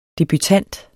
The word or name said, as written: debutant